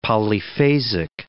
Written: polyphasic

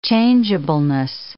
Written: changeableness

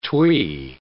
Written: twee

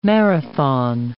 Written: marathon

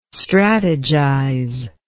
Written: strategize

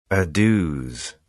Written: adieus

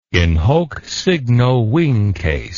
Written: in hoc signo vinces

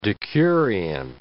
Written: decurion

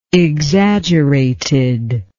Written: exaggeratedness

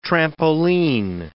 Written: trampoline

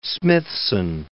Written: Smithson

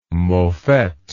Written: mofette

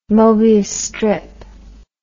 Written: mobius strip

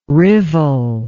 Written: rivel

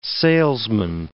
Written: salesman

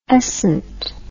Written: escent

